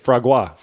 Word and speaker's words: foie gras: How do you say it? Foie gras.